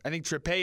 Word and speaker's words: Trippier: Fifth minute, they got a free kick, and Kieran Trippé is how it's pronounced, but either way, this is how it sounded from Moscow. – It is Kieran Trippier.